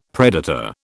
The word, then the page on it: predator: British English